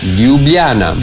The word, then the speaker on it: Ljubljana: Ljubljana.